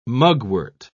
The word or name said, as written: mugwort